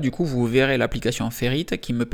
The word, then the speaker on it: Ferrite: Aujourd'hui, on enregistre donc ce podcast avec l'application Ferrite. Ferrite, si vous voulez le prononcer en anglais, Ferrite,